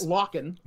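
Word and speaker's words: Lorcan: Locken. That's how you pronounce it. It's French.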